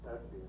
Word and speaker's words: Tesche: My name is Alan Tetchy, and that's spelled T-E-S-C-H-E.